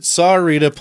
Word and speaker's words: Sahuarita: I think it was a neighboring city of, say this right, Sahuarita.